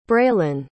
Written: braelynn

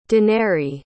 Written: denarii

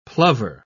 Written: plover

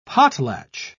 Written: potlatch

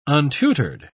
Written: untutored

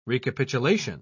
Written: recapitulation

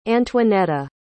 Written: antoinetta

